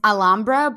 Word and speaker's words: Alhambra: No, it's Alhambra, but it's got a silent H after the L.